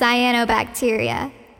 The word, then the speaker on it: cyanobacteria: Also, you said it wrong, sweet pea. It's pronounced cyanobacteria. No one gets it right the first time.